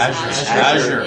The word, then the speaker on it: Azure: Is it Azure? How is Azure. – It? – Azure. – Yeah. – Azure. Like jazz. Okay. – Yeah. – But Azure at the end.